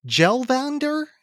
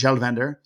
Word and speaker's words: Kjellvander: You don't pronounce the K, right? – Yeah. This is a must hear album. So it's K-J-E-L-L and then Vander, V-A-N-D-E-R. But it's, you know, I think it's pronounced Kjellvander.